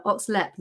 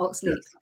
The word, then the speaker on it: Oxlep: And that's why I got in touch with, I'm going to say it correctly now, Oxlep, not Oxleep.